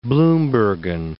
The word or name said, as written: Bloembergen